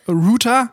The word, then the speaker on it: Router: Router. man kann es, glaube ich, nicht cool aussprechen, oder? Wie spricht man Router? – Router.